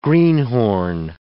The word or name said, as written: greenhorn